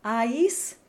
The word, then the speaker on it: aïs: So the I in aïs is a separate syllable from the A that's right next to it.